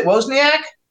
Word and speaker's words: Wozniak: And he's been described by Apple co-founder Steve, how do you pronounce it, Wozniak? – Yeah.